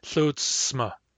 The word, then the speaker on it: tlutsma: A lot of people just pronounce the C and don't pronounce the S, but the conservative pronunciation is tlutsma. Tlutsma, with both a C and an S.